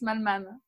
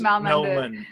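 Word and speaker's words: Mallmann: Francis Malman. – Francis Malman, the Argentinian.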